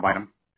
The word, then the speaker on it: Bynum: Janelle, last name Janibium. From 0 to 100, how do you rate your feelings? – That's Janelle Bynum is how you pronounce her name.